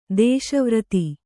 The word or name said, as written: ದೇಶವ್ರತಿ